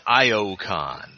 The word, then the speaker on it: Iacon: They're going way down to Iacon. Yay! – I believe you mean Iocon. – Yeah. – Says Thrust. – Thrust says that, but the others pronounce it correctly.